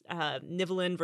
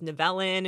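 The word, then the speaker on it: Nivelen: Well, they pronounce it multiple ways. Like the people making the show pronounce this name multiple ways because in the show they say Nivelin, but the actor who plays Nivelin, I was watching some behind-the-scenes stuff, and he pronounced it Nevelin.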